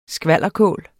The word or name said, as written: skvalderkål